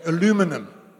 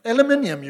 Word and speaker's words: aluminium: Now, if you're an American, you put the emphasis on the wrong syllable. You know what I'm saying? Aluminum. Aluminium, you wally.